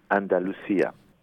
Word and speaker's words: Andalusia: Andalusia.